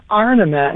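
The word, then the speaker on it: ornament: People would ask me, what's the deal with the St. Louis Arnament? And I said, oh, well, that's kind of how we pronounce it.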